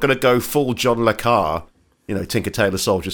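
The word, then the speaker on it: le Carré: The second person to correct me on the pronunciation. It's John Le Carre. I'm such an idiot. – I think so, yeah. – Anyway, John Le Carre.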